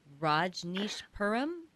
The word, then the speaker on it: Rajneeshpuram: Rajneeshpuram? – Yep.